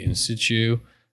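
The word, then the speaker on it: in situ: So this is real time, the location you're at, the in-situ. – There you go. – What are you laughing at? – Well, I thought that's how I pronounce it. – That's the right way.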